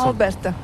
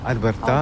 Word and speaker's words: Alberta: Comment on prononce ? – Alberta. – Alberta. Alberta.